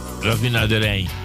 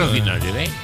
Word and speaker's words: raffinaderij: Ja, ging allemaal over oliemaatschappij, eh, uh, eh, eh, Raff, nee, hoe spreek je dat? – Raffinaar... Raffinaderij. Raffinaderij? Ja. Soms blijf je struikelen. – Raffinaderij. – Raffinaderij, ja. Oké.